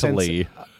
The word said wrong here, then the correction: correctly: I said collectly, and I meant to say correctly.